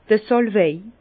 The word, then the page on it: d' Sàlwei: Bas-Rhin; Herrlisheim